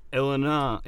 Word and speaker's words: Illinois: And on the 24th, a small group of people gathered outside Dorothy's house, which is in Illinois, that's how you say it.